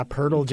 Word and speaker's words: Poeltl: I think you got a good pick with Poeltl, if that's how you say his name. – Yeah, Poeltl, Jakob Poeltl.